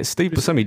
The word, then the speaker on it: Buscemi: Yeah, Steve Buscemi dressed as Buddy Holly, man.